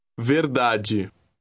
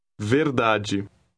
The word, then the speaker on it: verdade: Remember, D plus E or I is pronounced G. – Read word 11. – Verdade. Verdade.